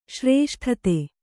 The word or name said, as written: ಶ್ರೇಷ್ಠತೆ